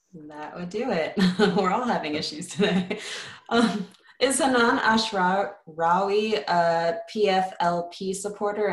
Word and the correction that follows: Hanan Ashrawi: Hanan Ashrawi,